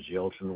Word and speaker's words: Yelton: Lauren, L-A-R-R-E-N. Last name is Yelton, Y-E-L-T-O-N.